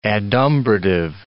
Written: adumbrative

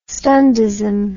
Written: stundism

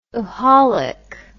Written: oholic

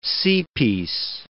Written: seapiece

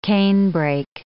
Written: canebrake

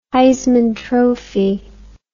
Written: heisman trophy

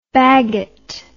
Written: baggot

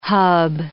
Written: hub

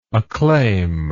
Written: acclaimer

